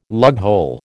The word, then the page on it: lughole: American English